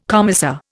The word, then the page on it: commissar: Indian